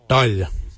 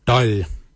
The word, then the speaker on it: dhaoly: Your quan is spelled G-W-A-N. Quan is your backside. Thigh. We say dal. Dal. Dal is spelled D-H-A-O-L-Y.